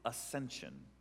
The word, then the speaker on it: ascension: Now Pentecost, I'll give you my one word for Pentecost, is ascension. A-S-C, if you're spelling it out. A-S-C-E-N-S-I-O-N? Ascension? I can't spell aloud. There's a silent C.